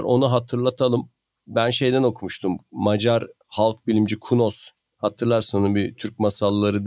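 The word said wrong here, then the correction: Kúnos: yine düzeltiyor olmuyorum ama Kunoş okunuyor olabilir. Kunoş mu? – Tamam.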